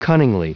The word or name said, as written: cunningly